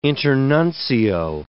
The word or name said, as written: internuncio